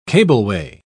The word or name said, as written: cableway